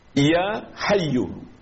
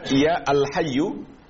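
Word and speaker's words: Ya Hayyu (يا حي): Ya Hayyu. Jangan kita baca ya al-hayyu atau ya hayyun.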